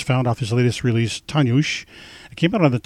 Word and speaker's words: Dagur: All this music from the release Tanjush, which means day in Icelandic, but it looks like it's spelled as D-A-G-U-R.